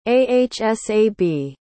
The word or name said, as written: ahsab